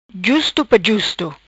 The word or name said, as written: Justo pa justo